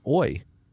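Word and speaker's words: oi (אוי): It's spelled Aleph Vav Yod with a cholam above the Vav. And it's oi. And it means woe.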